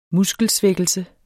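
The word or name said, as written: Muskelsvækkelse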